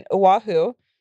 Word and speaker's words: Oahu: This story took place a few years back when I was living in Oahu. Is that how you say it? – Yeah.